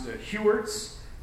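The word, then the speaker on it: Heuertz: This guy's got an amazing last name that I believe is pronounced Hewerts.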